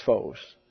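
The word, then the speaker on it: phos (φῶς): But this is a long O here. It's an omega. We pronounce it as if it were an omicron. And give it sort of a short A sound in phosphorus. But it's phos.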